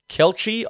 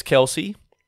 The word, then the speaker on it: Kelce: Firstly, this Taylor Swift, Travis Kelce, I want to say. Kelchi, I could easily hear it's pronounced Kelchi, but I think it's Kelsey.